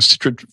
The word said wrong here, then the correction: Chytrid: fungus? Chytrid fungus? – Chytrid. Yes.